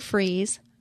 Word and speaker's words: Fries: So Sue Freeze, and my name last name is spelt like fries, pronounced freeze, like French fries, .com.